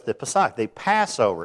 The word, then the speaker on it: Pesach: But you spell it P-E-S-A-C-H, which doesn't sound like Pesach at all, but that's just the way it is. And if you don't say it right, it's say Pesach. Can you do that? It's guttural. It's a guttural language, and so...